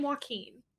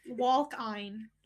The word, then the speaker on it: Joaquin: I was like, oh, that's a very cool name, because I thought it was spelled W-A-L-K-I-N-E. – Walking. Walk-ein. Like, walk.